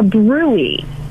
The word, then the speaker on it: bruit: You want the doctor to check, put their stethoscope on your belly and check for what's called a brewy. Brewy is spelled brute. Now you'll never forget it. B-R-U-I-T.